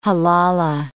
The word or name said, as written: halala